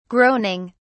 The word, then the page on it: groaning: British English